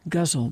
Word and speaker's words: ghazal: Guzzle by Duane Neatum. And let's be clear that guzzle is spelled G-H-A-Z-A-L. We are talking about probably an Arabic pronunciation.